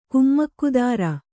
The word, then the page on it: ಕುಮ್ಮಕ್ಕುದಾರ: kummakkudāra